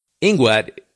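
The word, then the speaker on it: INGUAT: Ingwat is the pronunciation of the first letters in the Department of Tourism, etc., etc., in Guatemala. And actually,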